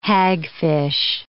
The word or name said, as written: hagfish